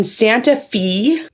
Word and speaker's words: Santa Fe: It looks like it's Santa Fe, but it's not.